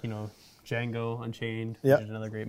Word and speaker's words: Django: So good that you're saying Django again. – I know. I kept saying the Django for the longest time. – The D is silent.